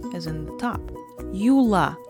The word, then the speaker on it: Юла: In first case, you have a, Юла, and of course, the last syllable is stressed.